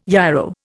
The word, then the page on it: yarrow: Indian